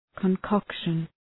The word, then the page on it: concoction: {kɒn’kɒkʃən}